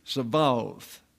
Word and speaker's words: Sabaoth: And that means it's not pronounced as a B, it's a V, Sabaoth.